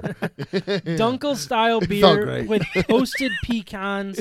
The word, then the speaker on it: Dunkel: Dunkel? How would you say that word? – I would say dunkel. – Yeah, dunkel.